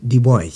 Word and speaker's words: Du Bois: son nom s'écrit Dubois, ça se prononce Dubois.